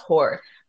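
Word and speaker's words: HO99O9: It's pronounced Whore, but it's spelled H-O-99-09, right?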